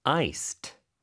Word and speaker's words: iced: 그래서 I-C-E-D가 들어가는데 발음은 iced, 이렇게 t 발음으로 됩니다. iced가 아니에요. iced, 이렇게 발음이 됩니다. 자, 그랬더니 대답으로는 ice로 주세요. – iced, please. – 역시 또 iced, capital I-C-E-D.